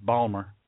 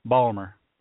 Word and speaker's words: Baltimore: Balmer. Balmer. That's how they say it down there.